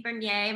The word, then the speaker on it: Bernier: Hey guys, you can follow me on all social media platforms at Missy Bernier. My last name is spelled B-E-R-N-I-E-R.